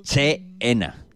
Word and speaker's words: China: Dicho para los escuchantes, Che-ena. – Che-ena. – Pero nosotros, como te pronunciamos muy bien en inglés, decimos China.